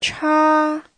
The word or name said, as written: cha1